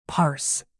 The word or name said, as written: parse